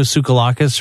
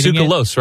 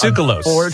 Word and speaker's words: Tsoukalos: I think it's Tsoukalos, right? Isn't that how you say it? – I wish I could say I was working with Giorgio Tsoukalakis, but it's Tsoukalos. – It's Tsoukalos. No, it isn't. It's Tsoukalos.